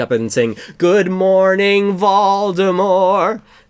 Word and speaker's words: Voldemort: Well, technically, it's not supposed to be pronounced Voldemort. It's supposed to be pronounced Voldemort. You don't pronounce the T, from it's what French.